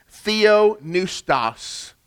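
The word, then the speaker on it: Theopneustos (θεόπνευστος): Theopneustos. Theo, God. Theology, right? Theophany. Theo is of God. Neustos, like a pneumatic drill or like pneumonia. Has to do with our lungs, with our breath.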